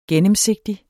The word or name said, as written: gennemsigtig